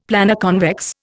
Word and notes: Planoconvex: Indian

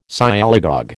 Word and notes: Sialogogue: American English

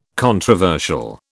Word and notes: controversial: British English

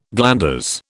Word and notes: glanders: British English

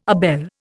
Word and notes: abele: Indian

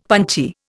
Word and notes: punchy: Indian

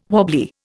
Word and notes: wobbly: Indian

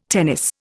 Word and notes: tennis: Indian

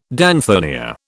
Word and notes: danthonia: British English

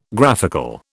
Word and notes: graphical: British English

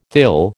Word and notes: thill: American English

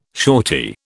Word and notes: shorty: British English

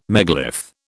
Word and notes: megalith: British English

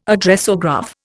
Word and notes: Addressograph: Indian

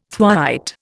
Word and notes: thwaite: Indian

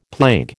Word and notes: plague: American English

